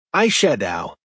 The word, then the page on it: eyeshadow: American English